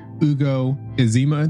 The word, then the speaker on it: Ezema: I am a new intensivist as of, you know, a couple months ago, newly board certified, and I'm privileged to have Dr. Ugo Ezema.